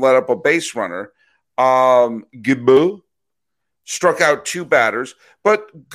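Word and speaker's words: Gibaut: Did I say it right, Gibault? – I think it's Gibault.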